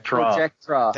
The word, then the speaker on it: Projectra: He challenged Princess Projecta, I can't say her name for some reason. – We call that Projectra.